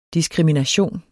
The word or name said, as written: diskrimination